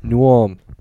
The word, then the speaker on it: New Ulm: Let me tell you how the locals say it. It's New Ulm.